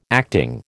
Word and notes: acting: American English

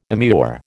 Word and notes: immure: American English

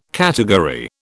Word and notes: category: British English

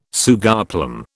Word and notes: sugarplum: British English

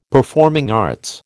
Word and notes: performing arts: American English

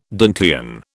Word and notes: Dantean: British English

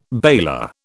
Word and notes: baler: British English

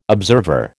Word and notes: observer: American English

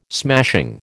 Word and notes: smashing: American English

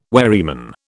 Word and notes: wherryman: British English

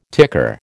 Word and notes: ticker: American English

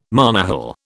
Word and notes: monohull: British English

